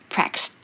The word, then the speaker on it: practiced: 那一样，我常常说，在发音的原则里面呢，其实如果是那个是无声的话，那你后面那个 d 是不会念出来的嘛，所以是 practiced。